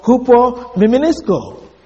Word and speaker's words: hupomimnesko: So, but the word reminder is a Greek word, hupo mimenisko. Hupo mimenisko is spelled H-U-P-O-M-I-M-N-E-S-K-O. Hupo mimenisko.